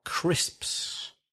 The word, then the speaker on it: crisps: He was opening a packet of crisps. – Very difficult to pronounce that, papas. Crisps. A packet of crisps.